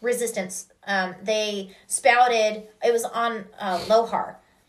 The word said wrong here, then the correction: Lothal: mm-hmm. I think is how you pronounce his the the city's name that he grew up in, and they um, they protested the uh, the empire's occupation of Lohar or Lothal. – Lothal. Lothal.